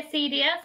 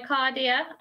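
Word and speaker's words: acedia: I think there are different pronunciations. I say acidia. Some people say acardia. I think it's a little bit open to interpretation.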